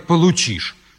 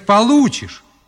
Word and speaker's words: получишь: Николай Владимирович, нельзя говорить «получишь». Надо говорить «получишь». Вы неправильно ударение ставите.